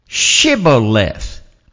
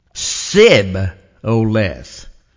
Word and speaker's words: Shibboleth: If he said no, then they would say to him, then say Shibboleth. And he would say, Sib-o-leth, for he could not pronounce it right.